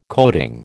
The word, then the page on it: coating: American English